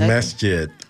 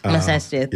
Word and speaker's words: Masjid: It is the 6th annual Black History Month program at the Masjid and Nasea. I hope I said that right. – Okay. It's okay. The Masjid. – Masjid. Thank you.